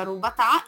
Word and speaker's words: robatà: La forma più antica e tradizionale del grissino è il robatà, che si pronuncia rubatà e significa rotolato,